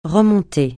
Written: remonter

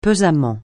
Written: pesamment

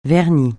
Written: vernis

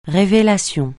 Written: révélation

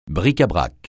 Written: bric-à-brac

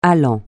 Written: allant

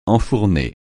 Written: enfourner

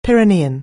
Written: Pyrenean

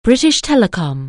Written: British Telecom

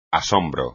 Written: asombro

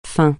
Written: fin

